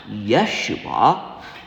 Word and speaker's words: Yeshua: And Jesus, or, because this is the Feast of the Holy Name, more correctly pronounced Yeshua, is also an important name, as Yeshua is where we get our name Joshua.